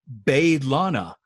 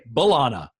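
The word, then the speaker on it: B'Elanna: She pronounces it Baylana, because the rest of the show, we say Balana, right? We don't really say Baylana, but she says Baylana.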